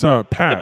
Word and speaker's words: Patch: It's Patch. – The first name, it's P-A-T-C-H, oh. Patch. – P-A-T-C-H.